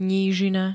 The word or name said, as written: nížina